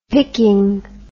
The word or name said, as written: pickin